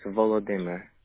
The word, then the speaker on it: Volodymyr: Okay, and you just spell your first name Vova, V-O-V-A? – And it's fully, if you want to fully spell, it's Volodymyr. – Okay, V-O-L-I. – No, V-O-L-O-D-Y-M-Y-R.